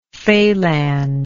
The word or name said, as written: falange